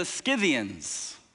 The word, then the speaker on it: Scythians: Their literal ancient, Josephus calls them, said they, they moved to the land of the, uh, the, um, the Scythians. Let me make sure I'm pronouncing that right. And so Josephus said, yes, the, the land of the Scythians.